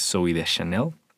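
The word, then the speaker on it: Zooey Deschanel: Pero sí, es Zoe de Chanel.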